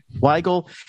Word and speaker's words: Weigel: Weigel.